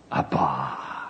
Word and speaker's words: Abba: A-B-B-A. The accent on the last syllable.